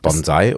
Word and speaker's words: Bonsai: Betonung liegt auf dem N. Bonsai. – Bonsai. – Und bei dir auf dem A. Bonsai.